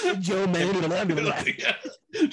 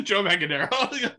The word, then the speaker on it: Manganiello: Joe Manganiello. – Joe Manganiello.